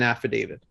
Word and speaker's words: affidavit: Affidavit? – Yeah, affidavit. – Yep. That's how it's pronounced? – Yeah, yeah.